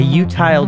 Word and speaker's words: utile: Before I dive in, I just for everybody listening, I'm going to mention something called, it's spelled U-T-I-L-E. And I have a trusted source that tells me that that's pronounced like futile, like utile. But I've been listening to a lot of game theory audio or like lectures and everything like that. All exclusively by British people who have pronounced it utile in the same way that you would say futile.